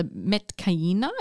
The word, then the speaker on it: Metkayina: The design of the characters themselves, so the Metkayina, is that how you say it? – Yeah, about that.